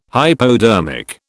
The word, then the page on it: hypodermic: British English